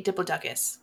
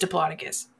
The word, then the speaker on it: Diplodocus: Unless you say Diplodocus, because that is fucking wrong. It's Diplodocus. Thank you. – I will say it how I want, which is Diplodocus.